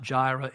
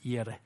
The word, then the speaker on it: Yireh: So they would say Adonai, and it's not Jireh, it's Yireh. Adonai Yireh is the way they would pronounce that.